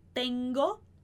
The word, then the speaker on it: tengo: Então o primeiro G que aparece na palavrinha tengo é o G, é o som do gato.